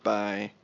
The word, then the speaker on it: Pai: I've heard it's pronounced Pai.